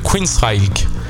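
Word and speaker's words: Queensrÿche: Alors, ça se prononce Queensrÿch. Rÿch avec un L? Non, c'est bizarre. Queensrÿch, je dirais plutôt, moi.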